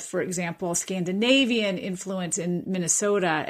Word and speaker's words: Minnesota: And that's why, of course, we say Minnesota, because that's actually the monophthongal residue, to use a big word, of Scandinavian pronunciation, where the vowels were shorter and they don't have that little extra piece on the end like Americans like to say, like, oh, we say oh. But if you're from Finland or Sweden, you'd say oh, oh. So Minnesota. It becomes a shorter pronunciation.